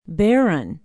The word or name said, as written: barron